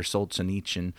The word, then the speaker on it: Solzhenitsyn: Solzhenitsyn. And I'm sure I'm not pronouncing that correctly. – Oh, you're pretty close on that.